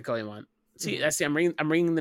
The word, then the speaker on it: Goemon: Nope, Goemon is how they say it in the movie.